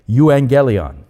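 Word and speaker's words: euangelion (εὐαγγέλιον): Now, the word itself comes from the biblical word for gospel in the original Greek language. It's pronounced euangelion.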